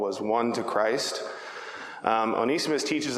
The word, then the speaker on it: Onesimus: I always want to call him Onesimus, but his name is pronounced uh, Onesimus.